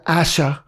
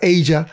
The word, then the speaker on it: Aja: Pero en esta ocasión les traigo el Aja, que por cierto leí en Wikipedia que se pronuncia Aja, pero en la pinche rola estos güeyes cantan Aja.